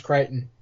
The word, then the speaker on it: Crichton: It is, but you're right, it is pronounced Crichton.